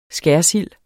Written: skærsild

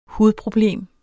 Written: hudproblem

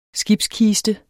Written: skibskiste

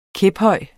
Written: kæphøj

